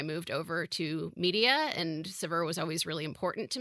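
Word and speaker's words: Saveur: If I were in France, I would probably say Savour. But since I'm in America, I say Sever. And probably the strangest way that I've ever heard it pronounced is Savior, which I gotta say is a little weird, but also kind of wonderful.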